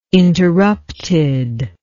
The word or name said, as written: interrupted